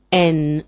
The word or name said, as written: en